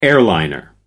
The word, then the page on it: airliner: American English